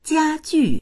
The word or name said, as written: jia1ju4